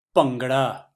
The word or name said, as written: Bhangra